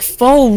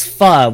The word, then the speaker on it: pho: No, because that word is pho, which means you're pronouncing it wrong.